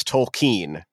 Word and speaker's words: Tolkien: Yes, it's Tolkien is how it goes.